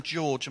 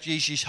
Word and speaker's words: Jiří: And we just really appreciate, should I call him George, am I saying it wrong? Okay. We really appreciate Jiří's heart.